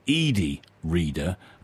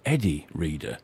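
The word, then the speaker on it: Eddi Reader: She was the lead singer of Fairground Attraction, and I've heard it pronounced as Edie Reader, or also Eddie Reader.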